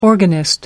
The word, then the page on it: organist: American English